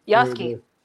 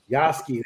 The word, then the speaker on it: Yasuke: Yasuke. Yasuke.